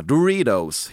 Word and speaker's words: Doritos: Du uttalar Doritos på amerikanska. Doritos, helt enkelt.